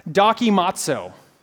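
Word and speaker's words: dokimazo (δοκιμάζω): And it's called, the way it's pronounced is dokimazo. Dokimazo.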